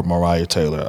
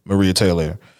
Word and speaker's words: Maria Taylor: Maria or Mariah Taylor. I don't know if I'm pronouncing that correctly. – Yes, Maria. – Maria Taylor.